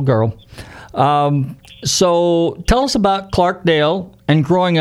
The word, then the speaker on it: Clarkdale: Yeah, you're one of the few guys that didn't say Clarksdale, but it is no S, Clarkdale, right? – Okay, and now I have to say this.